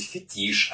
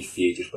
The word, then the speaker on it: фетиш: И я прям такой, а, ударение неправильно, правильно говорить фетиш, а не фетиш, потому что слово французского происхождения и прочее.